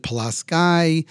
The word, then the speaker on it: Pulaski: They pronounce it Pulaski.